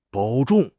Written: 保重

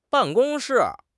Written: ban4 gong1 shi4

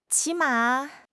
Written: 起码

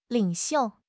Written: 领袖

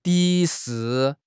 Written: 敌视

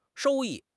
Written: 收益

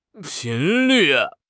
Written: 频率

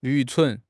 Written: 愚蠢

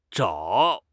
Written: zhao3

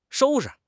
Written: shou1 shi5

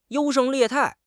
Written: 优胜劣汰

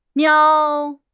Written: niao3

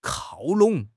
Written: 靠拢

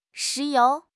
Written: 石油

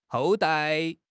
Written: 后代

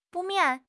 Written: 不免

